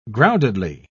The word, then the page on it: groundedly: British English